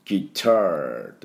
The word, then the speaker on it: guitar: で、 electric guitar、 electric guitar ってなる言う音になりますが、あと guitar のところは喉をグッグッグッグッグッグッグッグッグッグッって鳴らして、で、 guitar、 R ですね、 tar、 guitar というふうに音になります。